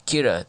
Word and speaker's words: Kira: whom the general public has been calling Kira, the Japanese pronunciation of the world. Of the English word killer.